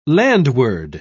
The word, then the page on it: landward: British English